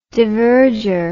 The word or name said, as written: diverger